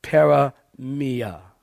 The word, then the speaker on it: paroimia: The other New Testament word referring to a veiled statement is the Greek word paromia. Paromia. Let me spell it for you. P-A-R-O-I-M-I-A, paramia.